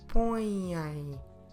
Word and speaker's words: põem: our verb form in Portuguese is põem. Eles põem. Como se escreve põem? P, O, contil, E, M. Põem. Very nasal. So on this one, to indicate that it is nasal, we have the accent, til, and we also have the M.